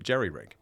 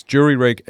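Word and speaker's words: jury-rig: And so you jury rig something. But we pronounce it jerry rig. Both of our countries do. – There we go. – It's jury rig and gerrymander.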